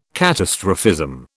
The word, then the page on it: catastrophism: British English